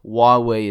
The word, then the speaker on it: Huawei: But what I do want to cover is the competition, particularly Samsung and Huawei is how you pronounce it.